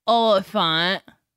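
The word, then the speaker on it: Olyphant: Timothy Oliphant.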